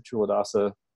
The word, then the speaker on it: Chula Dasa: When I started with Chula Dasa, I was pronouncing his name Kula Dasa, and some people, like, they hear Chula Dasa, Chula Dasa, Chula Dasa, and they still say Kula Dasa, because, you know, it's just...